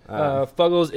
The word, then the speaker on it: Fuggles: Fuggles is, first of all, pronounced Fuggles, because F-U-G-G-L-E.